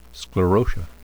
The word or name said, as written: sclerotia